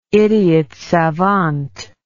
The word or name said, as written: idiot savant